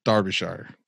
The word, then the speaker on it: Derbyshire: Okay, so it's not Derby shithole, it's Derbyshire. Derbyshire. Is that how you say it? Give me a break. I'm in Florida. – Yeah, absolutely. You're doing a good job.